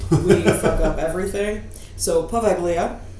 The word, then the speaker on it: Poveglia: If I were to not bastardize the pronunciation with my English speaking self, it would be something more along the lines of Poveglia.